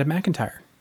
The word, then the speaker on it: McIntyre: And tonight we are joined by Nebula and Hugo Award-winning novelist Vonda McIntyre.